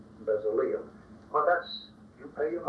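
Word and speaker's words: Bezaleel: And when he gave instructions to Moses to instruct Aholiab, and Bezaliel, you might not like that pronunciation. Most of your Bibles will say Bezaliel, but the Hebrew is more or less Bezaliel, and the El, of course, means God, and Bezaliel means the shadow of God.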